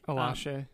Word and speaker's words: Alashe: Anyways, we got Fatai Alash. Probably not saying that right. – Alashe. – Alashe. Oh, man. I went for the silent E.